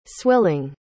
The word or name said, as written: swilling